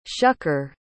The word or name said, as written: shucker